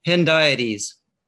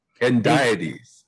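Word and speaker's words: hendiadys: Hendiades. – Hendiades.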